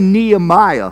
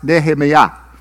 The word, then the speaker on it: Nehemiah: You know, for example, we say Nehemiah, right? That's what we call him, Nehemiah. But that's not even close. It's actually pronounced Nehemiah. Nehemiah. And we say Nehemiah.